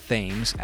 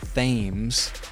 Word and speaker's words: Thames: It's not Thames as it is spelled. Might I remind you, silly Brits, T-H-A-M-E-S equals Thames in the English language. But apparently, according to my British friend, it's Thames, not Thames.